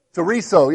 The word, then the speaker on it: chorizo: Chorizo, yeah.